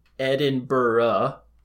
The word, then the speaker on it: Edinburgh: For your Scottish listeners, Edinburgh is pronounced Edinburgh.